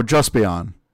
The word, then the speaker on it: Just Beyond: So his next question is more Tokusatsu is coming stateside, not just Sentai, but we've got Amazon's on Prime, US, we've got Juice Beyond. I'm probably saying that wrong. – Just think Beyond, so Justice and Champion. – Oh, Just Beyond.